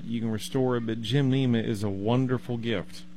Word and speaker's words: gymnema: It's called gymnema. And I will attempt to spell that for you. It's G-Y-M, just like you're going to go to the gym and work out. – Mm-hmm. – But it's one word. Gymnema is spelled G-Y-M-N-E-M-A.